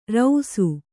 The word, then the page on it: ರೌಸು: rausu